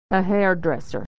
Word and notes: dresser: American English

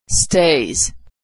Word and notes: stays: z

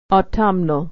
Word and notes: autumnal: au-tum-nal

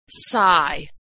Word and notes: sigh: American English

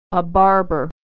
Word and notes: barber: American English

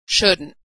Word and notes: shouldn't: American English

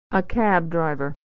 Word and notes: driver: American English

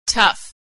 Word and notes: tough: American English

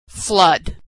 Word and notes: flood: female

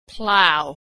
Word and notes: plough: American English

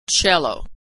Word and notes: cello: American English

